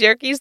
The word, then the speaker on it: Yerkes: And Walt was like, rhymes with turkeys, Yerkes turkeys.